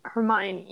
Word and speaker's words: Hermione: Hermione, yeah.